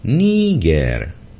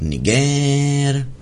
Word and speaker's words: Niger: století je ten název uveden správně, tedy Níger. A vystupuje v televizi jeden z takových flastenců, jmenovat ho nebudu, a jeden z vystupujících správně vyslovil Níger. A ten kovaný patriot na to, co to povídáte, to není správně, musíte vyslovovat Niger.